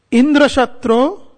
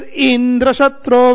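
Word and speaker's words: Indraśatru: But you see, when you say that with the emphasis on the first vowel, Indra Shatro Vivardhasva, Indra Shatro, then it means, let a son be born who is killed by Indra. But if you say Indra Shatro Vivardhasva, when the syllable, the final vowel is emphasized, is accentuated, then it means let a son be born who will be a killer of Indra.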